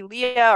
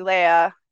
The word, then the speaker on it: Leah: But I mean, it's also the same with my first name. Like, it could be Leah or it could be Leah.